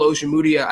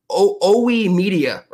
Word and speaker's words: Ojemudia: The most egregious mispronunciation of a name was Michael Ojemudia. I think they called him at one point OE Media or something like that.